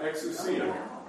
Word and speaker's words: exousia: Exousia. Exousia. Okay? The stress is on the C. Accent.